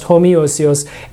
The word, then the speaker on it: homoiousios: Yeah, it was like, yeah, you put the I in there and it's pronounced homoiousios, and it's like saying, well, we can't say that they're the same thing, you know, equally divine, but they're similar.